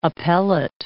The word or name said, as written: appellate